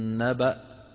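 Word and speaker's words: نبإ: الآية 34 اللي قرأها الآن الأخ آخرها ولقد جاءك من نبأ، انظروا إلى نبأ كيف مكتوبة، فيها ياء تكتب ولا تلفظ، ونقف عليها كما ذكرت من نبأ عادي، نقف عليها عادي كما نعتاد نحن.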